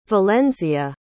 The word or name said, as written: valenzia